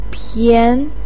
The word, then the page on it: pian2: pinyin